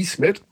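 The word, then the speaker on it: İsmet: Und sie hat Folgendes geschrieben, was die Aussprache des Namens Ismet Akpina angeht. – Ja. – Dass das I, das große I mit Punkt drauf, also bei Ismet, wird ausgesprochen wie das deutsche I in Miete.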